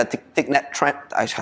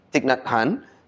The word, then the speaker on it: Thich Nhat Hanh: You know, some people, maybe they like a Thich Nhat Hanh. I keep butchering his name. I feel so bad. – Thich Nhat Hanh. – There we go.